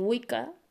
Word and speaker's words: Wicca: De ahí que Wicca eh, la pronunciemos con el sonido de K y no de CH.